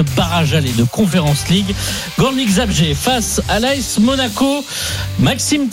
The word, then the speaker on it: Górnik Zabrze: Belle prononciation d'ailleurs pour le Górnik Zabrze.